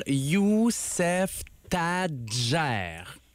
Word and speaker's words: Youssef Tadjer: Alors, il y a deux syllabes pour le prénom, il y a deux syllabes pour le nom de famille. Alors, on va y aller tout le monde ensemble. Youssef Tadjer. – Youssef Tadjer.